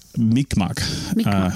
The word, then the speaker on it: Mi'kmaq: K-M-A-Q. – Oh, sorry. K-M-A-Q. Yeah. – yeah. – Mi'kmaq. – Mi'kmaq. – There's going to be like a pause. – Yeah. – Like Mi'kmaq.